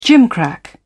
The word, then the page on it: gimcrack: British English